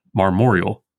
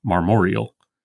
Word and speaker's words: marmoreal: In line 9, marmoreal, not marmoreal.